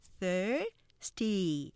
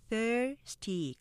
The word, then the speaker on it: thirsty: 첫 번째, 보통 우리가 thirsty 이렇게 삼음절로 하기 쉽지만 음절 보시면 thirsty 이음절로 하면서 앞에 액센트 강조되기 때문에 thirsty 강약으로 발음하시고요.